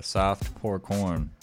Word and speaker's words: Soft Pour Corn: Right before that, we had Softcore Porn. I said that wrong. I'm so sorry, radio. Soft Pour Corn.